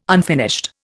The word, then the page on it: unfinished: Indian